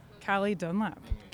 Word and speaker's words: Calee Dunlap: Calee Dunlap.